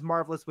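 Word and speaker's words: Marvellous: I am Johnny, also known as Marvelous Jonathan or Marvelous Johnny on TikTok, Instagram, or even Twitter. Just remember, that is Marvelous with two L's because I am Canadian and we spell it with two L's.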